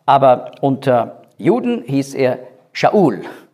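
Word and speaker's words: Shaul (שאול): Saul, das ist Hebräisch. Shaul ausgesprochen.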